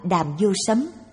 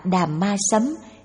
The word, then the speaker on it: Đàm Vô Sấm: Hán dịch âm là Đàm Vô Sấm, cũng còn đọc là Đàm Ma Sấm hay Đàm Ma La Sám, dịch nghĩa là Pháp Phong.